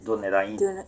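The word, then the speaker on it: Dúnedain: Dunedain.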